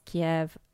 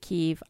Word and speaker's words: Kiev: Also, I know people pronounce Kiev and some people pronounce it Kiev.